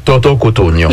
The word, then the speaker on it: Toto Cotugno: Toto Cotugno.